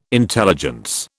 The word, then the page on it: intelligence: British English